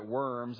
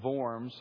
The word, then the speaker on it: Worms: And it was in the city, as we read the word, it looks like Worms. It'd be pronounced Worms.